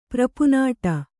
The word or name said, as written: ಪ್ರಪುನಾಟ